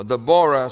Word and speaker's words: Deborah: Deborah, or Deborah, some people pronounce it, is herself called a Mother of Israel.